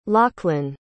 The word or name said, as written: lauchlan